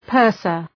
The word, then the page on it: purser: ‘pɜ:rsər